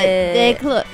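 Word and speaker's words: Descloux: Suburban Lawns, a janitor, Crack Cloud with Philosophy's Calling, and at the top was Lizzie Mercier de Clos. I don't know how to say the French. – I can't see it, but de Clos. You just say it fast.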